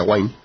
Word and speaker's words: Wine: Wine también, se escribe Wine, pero se pronuncia Wine.